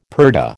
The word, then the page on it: purdah: American English